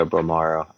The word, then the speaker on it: Bolmaro: Marry, fuck, kill, RJ Hampton, Tyrese Maxey, Leandro Bolmaro. I hope I pronounced his name correctly. – Yeah, Bolmaro.